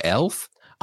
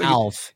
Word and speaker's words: Alf: I don't think I've ever seen Alf when because you've talked about Alf before, but the way you pronounce it sounds like Elf. – I know. I think it's supposed to be Alf.